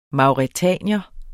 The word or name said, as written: mauretanier